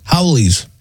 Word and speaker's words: haole: We're going to beat up the," I don't know what this term is, H-A-O-L-E. – Howleys. – What is it? – Howleys.